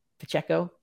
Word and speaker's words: Pacheco: Pacheco.